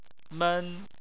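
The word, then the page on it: men: tone 1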